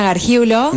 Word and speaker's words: Gargiulo: Em, pueden encontrarme en Instagram como psicóloga Josefina Gargiulo.